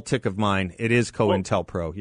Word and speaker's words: COINTELPRO: I heard you reference in an earlier segment the FBI's erstwhile, what you called COINTELPRO fiasco. And it's actually an acronym that's pronounced COINTELPRO.